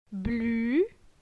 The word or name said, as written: Blu